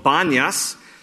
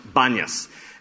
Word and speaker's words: Banias: But instead of it being Panias with a P, it is called Banias, because that is Arabic for Panias. But there is no P in the Arabic language, and so they can't pronounce P's. And so in Arabic, Panias is pronounced Banias.